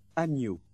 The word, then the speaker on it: agneau: And the combination GN is pronounced NY, agneau.